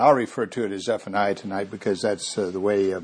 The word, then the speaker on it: Zephaniah: You know, the I-A-H, like Nehemiah or Jeremiah or Zephaniah. In the Hebrew, I believe it's Y-A-H on the end, Yah, standing for God, Yahweh. And so probably a better Hebrew pronunciation of this word would be Zephaniah.